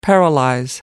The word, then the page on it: paralyze: British English